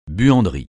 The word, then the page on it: buanderie: francais